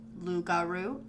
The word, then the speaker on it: loup-garou: Pronounced Lugaru and Rugaru, respectively. These words rhyme with each other because Rugaru is the variant of French Lugaru.